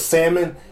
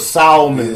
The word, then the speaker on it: salmon: He forever be trolling when he puts salmon like. He knows people. Some people pronounce the L in it, so he put salmon. Yeah, like salmon.